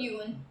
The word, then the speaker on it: Ewan: Ewan.